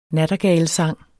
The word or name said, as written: sangene